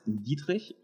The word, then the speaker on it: Dietrich: Bueno, como yo lo pronuncio en alemán es Dietrich y la universidad es Technische Universität Kaiserslautern.